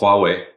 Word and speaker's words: Huawei: Huawei, yep.